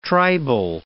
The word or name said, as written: tribal